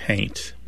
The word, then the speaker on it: haint: Somebody might spell it H-A-U-N-T, but pronounce it haint.